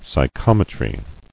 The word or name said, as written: Psychometry